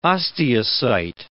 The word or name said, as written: osteocyte